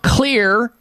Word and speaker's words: Xlear: Find out about Clear Rescue Nasal Spray. Clear is spelled with an X. X-L-E-A-R. X as in X-ray. X-L-E-A-R.